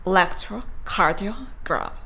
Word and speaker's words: electrocardiogram: えー、この単語の発音の一番大切なポイントは、アクセントを 3 つ置いて発音することだとお話をさせていただきました。 electrocardiogram。 3 つですね。